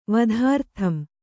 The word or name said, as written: ವಧಾರ್ಥಂ